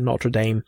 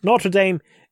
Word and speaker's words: Notre Dame: And I've come across some weird ideas about the fire at Notre Dame. – Yeah. – Sorry, Notre Dame. It's silly. We shouldn't pronounce it as Notre Dame.